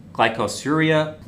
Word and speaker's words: glycosuria: Within a few days, glycosuria, is that how you pronounce that? – Glycosuria. – Okay.